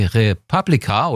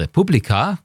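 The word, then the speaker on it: re:publica: 30.000 Besucher, Europas größte Digitalkonferenz, die Republika oder Republika, können Sie aussprechen, wie Sie wollen, tagt in Berlin.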